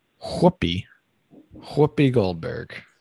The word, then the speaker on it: Whoopi Goldberg: Whoopi Goldberg.